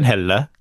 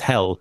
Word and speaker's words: Helle: Well, so his name is Torben Helle. A slightly Swedish pronunciation. Yep. It's a bit silly. And Helle just means sort of bright in the Scandinavian languages. But Of course, he's living in England, so everyone just says Helle.